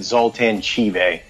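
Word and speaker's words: Zoltan Chivay: He pronounced it Zoltan Chive. – Chive, okay, that's good. With Field Marshal Windbag, which he's a parrot, right? – Yes, he's a very boisterous parrot.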